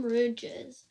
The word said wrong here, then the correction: rogue: Okay, so now you know you didn't pronounce that right. It's Rogue.